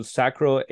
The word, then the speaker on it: sacral: Pronunciation hopefully is correct. So sacral agenesis. – Yeah. – Okay. Sacral. Thank you.